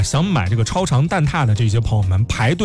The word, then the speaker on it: 蛋挞: 正确的读音是蛋挞，不读蛋挞哦。